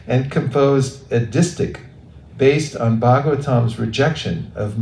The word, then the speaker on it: distich: It's pronounced distich.